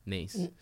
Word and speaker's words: Neis: Niece, N-E-I-S, though pronounced like your brother's child, Niece. – Not pronounced, like... Not spelled N-E-I-C-E.